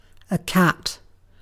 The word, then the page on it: cat: British English